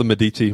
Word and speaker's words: Medici: But it is called the Medici Project. I actually said it correct.